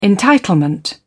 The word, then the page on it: entitlement: British English